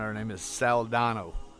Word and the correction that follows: Saldana: Saldano. – Zoe Saldana. – I'm sure I'm not pronouncing that right. – Saldana. – S-A-L-D-A-N. Saldana.